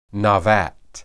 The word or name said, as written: nabat